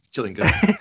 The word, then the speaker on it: Gillingham: Is that how you say it? Gillingham? Gillingham? – please, Gillingham. Yeah, first time.